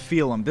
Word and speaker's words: Phelim: I think it's pronounced Phelim. This is an Irish name, wholehearted.